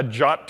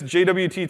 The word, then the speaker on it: JWT: And this token is what's called a JOT token, which is how you say JWT, JSON Web Token. It's one of the only specs that I know that like the first sentence of the spec says, you pronounce this JOT. I guess they just really didn't want to have like the GIF versus JIF controversy, so it's JOT, not JWT. So I take this JWT token, and a JSON Web Token is a Base64 encoded JSON string.